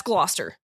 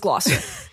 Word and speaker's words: Gloucester: I'm just that's Gloucester. – Okay. – That says Gloucester. That's in Massachusetts. – There seems like there's a lot of letters in there that aren't pronounced.